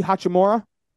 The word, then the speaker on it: Hachimura: Rui Hachimura.